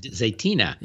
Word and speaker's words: Zaytinya: How do you say Zaytina? – Zaytina. Yes.